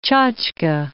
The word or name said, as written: tchotchke